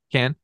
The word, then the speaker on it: Cannes: And then my other big pre-production thing before I get into something else is that Fargo premiered at the 1996 Cannes. – How do you say it? Cannes? – Yeah.